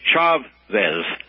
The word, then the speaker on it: Chavez: And the man's name is Chavez. C-H-A-V-E-Z. Yeah. The same way you would pronounce Perez, P-E-R-E-Z.